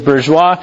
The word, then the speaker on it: bourgeois: Bourgeois. – All right, I was a little bit off. So the modern family is bourgeois.